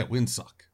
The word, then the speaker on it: Windsock: Sock? Is that how you say it? S-O-C-K? Sock? – Yeah, Windsock.